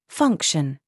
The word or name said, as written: SING